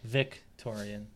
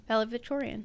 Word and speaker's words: valedictorian: What did I say? – Victorian. – Valedictorian.